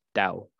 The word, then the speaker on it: Tao: Tao means path and is pronounced Dao.